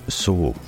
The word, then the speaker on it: sauge: Sage in French is pronounced sauge, or sage, depending on where you are in France. Sauge, though, is spelt S-A-U-G-E.